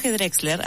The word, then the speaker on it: Drexler: Hoy elegí un tema de Jorge Drexler, así se pronuncia, porque estoy medio manija, ya que el viernes voy a ir a verlo por primera vez al Movistar Arena.